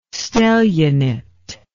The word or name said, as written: stellionate